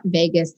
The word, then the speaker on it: vagus: It is not vagus, like what happens in Vegas stays in Vegas. It's spelled V-A-G-U-S.